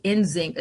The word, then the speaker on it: Nzingha: N-Z-I-N-G-H-A, Nzinga.